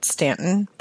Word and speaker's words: Staunton: The locals pronounce it Stanton, but there's an OU in there.